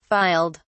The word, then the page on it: filed: British English